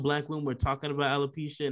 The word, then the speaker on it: alopecia: I'm going to pronounce it wrong. Alopecia? Is that how you? – Yeah, that's it.